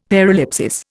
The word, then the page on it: paralipsis: Indian